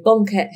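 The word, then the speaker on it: banquette: Is it bonquette? Bonquette? – Yes. – Okay, bonquette heavy.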